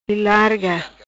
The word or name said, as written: Ilarga